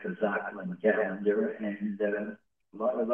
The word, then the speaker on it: Tzolkin: And 20 refers to the 20 solar eclipses as archetypes on the Zolkin calendar. That's spelled T-Z-O-L-K-I-N. Calendar. Yes. The Zolkin calendar.